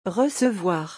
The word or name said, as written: recevoir